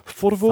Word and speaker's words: Forvo: Forvo.